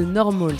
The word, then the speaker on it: NORML: Cette carte, vous la trouverez sur le site de Normal, ça s'écrit N-O-R-M-L sans le A,